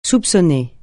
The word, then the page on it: soupçonner: francais